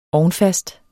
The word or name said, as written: ovnfast